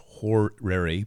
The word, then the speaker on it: horary: That's pronounced horary, by the way, H-O-R-A-R-Y.